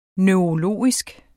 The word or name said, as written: neurologisk